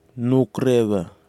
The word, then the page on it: noukrewha: Kwamera